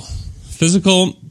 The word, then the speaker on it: Fyzical: Physical is a therapy and balance center. Physical is spelled F-Y-Z-I-C-A-L.